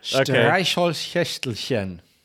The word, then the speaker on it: Streichholzschächtelchen: Streichholzschächtelchen.